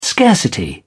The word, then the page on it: scarcity: British English